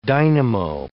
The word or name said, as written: dynamo